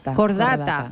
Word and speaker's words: Cordata: Algo así como Cudata. – Cordata. – Ah, mira, lo he dicho mal.